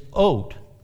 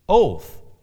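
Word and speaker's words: oath (אות): The Hebrew word translated mark in this verse, in verse 15, is oat, or it's pronounced oath. Oat or oath.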